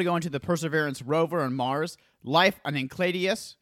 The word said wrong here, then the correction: Enceladus: Which is definitely not how you say it. Is it Enceladus? – It's probably Enceladus.